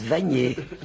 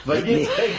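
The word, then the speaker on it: Wagner: Maintenant, monsieur, il y a le philosophe, là, moi, je l'appelle Finkielkraut. Je prononce tout à la française. Hein. Je dis les opéras de Vanier. – Oui. – Bon, voilà. – Vanier, Francis, voilà. Vanier, oui. Francisons un peu les choses.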